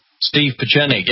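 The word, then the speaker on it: Steve Pieczenik: Your report, I think it was, on Steve Pachenik. Did I pronounce that right? – Steve Pachenik, yeah.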